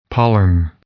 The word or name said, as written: pollen